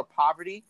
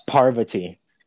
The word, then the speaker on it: Parvati: Some of the people, I call her Poverty. Like you live in poverty, but that's not her name. – It's Parvati.